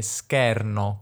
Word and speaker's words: scherno: Lui dice schermo e scherno con due E belle aperte, un po' come le direi io, ma in realtà giuro, non è piemontese Caparezza affatto, è pugliese, quindi però usa in questo caso anche qui due vocali aperte.